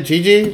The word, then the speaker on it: Gigi: Gigi? – Yes her favorite player was Trae Young.